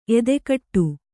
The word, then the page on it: ಎದೆಗೂಡು: edegūḍu